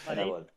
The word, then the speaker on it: The Stanley Parable: Es Parable.